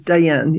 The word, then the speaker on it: Dayan: It's Dayan, yeah.